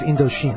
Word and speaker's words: Indochine: pronounced Indochine. Is that how you... It's Indochina, Indochine. I think it's Indochine. That sounds Indochine. – More French.